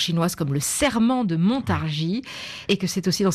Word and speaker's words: Montargis: On découvre que Montargis, on dit Montargis, hein, c'est bien ça, on ne prononce pas le S,